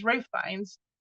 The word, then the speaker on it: Ralph: And no, it's not Ralph Fiennes, it's Ray Fiennes, but it's Ray Fiennes. So I literally learned that I've been saying his name wrong my whole life because...